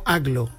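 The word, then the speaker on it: Agloe: Entiendo que Aglo, yo lo he escuchado pronunciado como Aglo.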